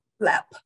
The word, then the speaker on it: Lemp: Lep, but it's spelled L-E-M-P. – L-E-M-P.